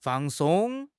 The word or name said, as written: fang4 song1